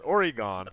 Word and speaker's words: Oregon: I suppose it's not worse, but Oregon. – Oregon. Yeah. Oregon. Yeah. – It rhymes with a fairly useless Pokemon.